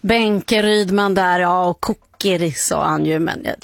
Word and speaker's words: Kukeri: För det är så det stavas, ja. – Med ett U. – Ja. Tycker jag vi säger, vi blir uppmanade här på Sveriges Radio att vi ska uttala det mesta svenskt om det går. – Precis. – Så vi säger Kukeri.